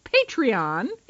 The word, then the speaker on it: Patreon: Our PayPal, postal address information, and I've been told it's pronounced Patreon information.